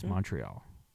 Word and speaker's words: Montreal: is Montreal.